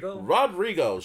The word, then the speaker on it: Rodrigo: Rodrigo.